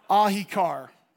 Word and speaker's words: Ahiqar: Can you say that with me? Ahikar. Okay? It's spelled A-H-I-Q-A-R.